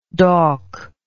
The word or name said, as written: dawkish